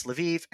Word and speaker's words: Lviv: In Ukrainian, it's Lviv, and in German, it's Lemberg.